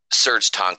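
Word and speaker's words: Serj Tankian: Serge Tankian.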